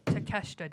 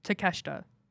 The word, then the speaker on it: Takeshita: So Takeshita, Dave, say it right. – To what? – Takeshita.